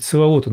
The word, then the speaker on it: Саваоф: Ну, Саваот он там произносится у них буквально.